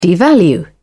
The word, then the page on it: devalue: British English